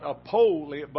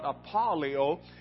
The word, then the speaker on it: apoleia: And this word, perdition, is spelled A-P-O-L-E-I-A. It is actually pronounced apolia, apolio, and it's not a long O, not apolia, but apolio. And this is a description of something that is so ruined that it goes into decomposing.